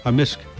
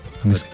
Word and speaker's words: amisk: Amisk. – Amisk. – Amisk. – So the K can be silent, but generally it's... – Yeah, amisk.